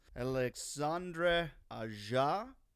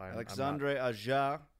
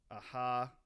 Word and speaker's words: Alexandre Aja: This episode, we're going to be discussing the new Gator creature feature, Crawl, directed by a man whose name I have come to find out I've been pronouncing all wrong. It's Alexandre Aja. – I don't even know my own name today, evidently. So Alexandre Aja. I believe I said aha in the in the preview message at the end of the last episode.